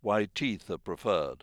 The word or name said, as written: white teeth